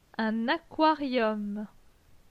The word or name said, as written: Un aquarium